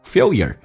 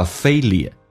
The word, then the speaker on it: failure: Failure，英式发音叫 failure，美式发音把 L 往前发 failure， failure， failure， failure works，你知道失败的用处吗？